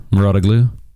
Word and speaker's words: Mouratoglou: Patrick Mouratoglou. The French. – Did you say that correctly? – Yeah, Mouratoglou. It's a weird spelling.